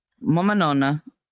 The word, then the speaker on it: nonna: My mama nun. Now, I had 2 mama nuns. Mama nonna is how you say it, but we cut everything down.